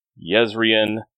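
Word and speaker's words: Jezrien: Basically, any name in a Sanderson book that starts with the letter J is pronounced with the Y sound. So, Mr. Yadith, Yezrian, What about Yules? – Was there a Jules?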